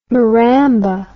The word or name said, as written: Maramba